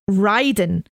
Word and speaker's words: Raiden: They've not even pronounced it right. We talked about that earlier. Should be pronounced Raiden.